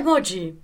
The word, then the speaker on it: Mocchi: En realidad es Mochi. – Ah, es Mochi. – O sea, es Mochi. En Italia sería Mochi, pero no vivo en Italia. Nunca viví.